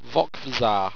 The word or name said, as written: vocvzar